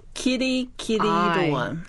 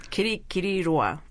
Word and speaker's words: Kirikiriroa: Kirikiriroa. – Kirikiriroa.